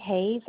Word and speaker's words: Hayes: It's Julie Hayes, H-A-Y-E-S, and I can give you a case number.